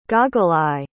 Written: goggle-eye

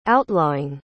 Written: outlawing

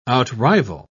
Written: outrival

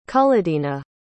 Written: cullodena

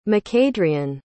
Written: macadrian

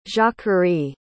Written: jacquerie

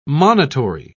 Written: monitory

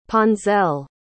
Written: ponselle

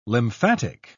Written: lymphatic

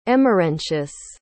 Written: emerentius

